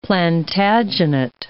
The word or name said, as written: Plantagenet